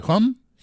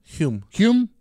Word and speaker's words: Hume: Hume. Hume.